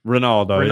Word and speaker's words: Ronaldo: The post came after Ronaldo. Is that how you say it? – Ronaldo.